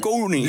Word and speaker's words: koningin: Dus een N voor die G en dan een beklemtoon I-N erachter. – Dat komt niet voor. – Dat is het enige woord in onze taal dat heeft. – Dat heeft. – En vandaar dat wij zeggen koningin.